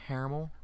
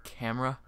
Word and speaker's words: caramel: I'm like, it's pronounced caramel, or caramel, not camera.